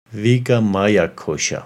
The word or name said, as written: Vigamaya Kosha